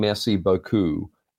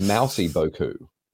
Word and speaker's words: merci beaucoup: kept mispronouncing merci beaucoup as mousy beaucoup, and I thought, well, that is a kid's character right there, mousy beaucoup, and her travels around the world.